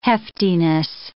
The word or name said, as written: heftiness